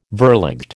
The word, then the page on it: verligte: American English